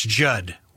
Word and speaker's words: Judd: That's Judd with two D's. The second D is silent.